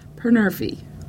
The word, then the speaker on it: Panurfy: And then because I'm crazy and can do that, I added a Y. So it's pronounced Panurfy. – Meow.